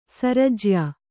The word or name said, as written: ಸರಜ್ಯಾ